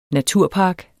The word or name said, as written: NATURPARKEN